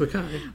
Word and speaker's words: Makai: I keep feeling like I say his name differently every time. – It's just Makai.